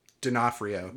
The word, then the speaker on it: D'Onofrio: Cartoon show in Vincent D'Onofrio.